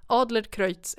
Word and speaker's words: Adlercreutz: där var det väldigt tydligt med att Adler Kreutz är Kreutz med Ö, så nu vet jag det.